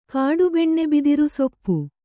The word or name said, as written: ಕಾಡುಬೆಣ್ಣೆಬಿದಿರುಸೊಪ್ಪು